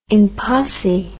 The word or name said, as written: in posse